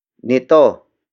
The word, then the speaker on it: nito: Nito. Nito. Nito is spelled as N-I-T-O.